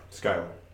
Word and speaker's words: Schuyler: Schuyler.